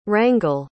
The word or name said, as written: Rangle